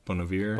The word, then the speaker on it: Bon Iver: Bon Iver? – Bon Iver.